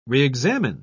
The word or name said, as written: reexamine